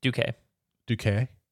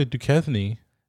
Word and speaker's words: Duque: I would say Duquesne. – No. It's Jack Duque. That's what they say in the show. – Like Duque. Like Dookie. Okay.